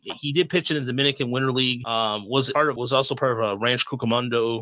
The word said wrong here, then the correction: Rancho Cucamonga: Is it Ranch Cucamonga? Am I saying that right? – Rancho Cucamonga. – Rancho Cucamonga.